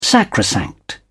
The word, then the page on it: sacrosanct: British English